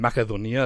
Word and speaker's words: Macedonia: Macedonia, that's how they say it in Greek.